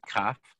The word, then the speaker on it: Kaf: Kaf, which is if you have a dot in the center of the letter Kaf, it's a hard Kaf. And if there's no dot, it's Ch. Chaf.